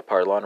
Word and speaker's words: parlons: Portuguese to French is going to be a parlon, right? Spelled P-A-R-L-O-N-S, right? That S is silent.